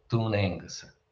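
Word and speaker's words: Dún Aonghasa: So that's how you pronounce the name of the fort on Inishmore, Oliver. So Dunengus.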